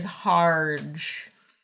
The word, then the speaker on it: Harge: And that guy's name is Harge, which rhymes with large.